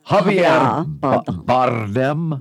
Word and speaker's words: Javier Bardem: Javier Bardem.